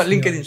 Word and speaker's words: LinkedIn: no, LinkedIn está bien.